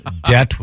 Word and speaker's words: debt: Debt with a silent B.